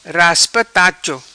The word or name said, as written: Raspa Tacho